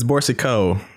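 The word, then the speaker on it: Boursiquot: And Johnny Borsicu, did I say that right? – No, you didn't. – I don't know how to pronounce names. – We're starting out well. It's Borsico.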